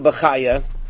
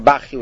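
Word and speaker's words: Bachya: The deeper meaning of the Pasuk, and it's really alluded to in Rabbeinu Bachaya, or Bachi, whichever way you pronounce it.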